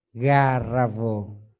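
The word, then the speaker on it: gāravo: trong kinh cái chữ mà lễ lễ độ này đó đọc là Garavon tức là có văn hóa, có ánh sáng của văn hóa.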